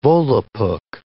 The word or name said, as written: Volapuk